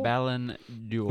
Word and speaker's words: Ballon d'Or: Is that how you say it? Ballon d'Or? – Ballon d'Or.